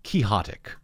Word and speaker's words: quixotic: He said, no, it comes from Don Quixote. Therefore, it is quixotic. Or quixotic.